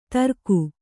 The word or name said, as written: ದರಕು